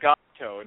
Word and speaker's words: Gopto: But the the book is called Gopto, and it's spelled G O P T O.